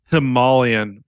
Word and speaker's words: Himalayan: It's Himalayan. Do continue. – Himalayan? – That's the correct way to say it.